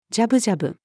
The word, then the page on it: じゃぶじゃぶ: female